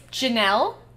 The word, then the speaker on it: Geniel: Her name is Janelle, but it's spelled Janiel. – Wow. – G-E-N-I-E-L.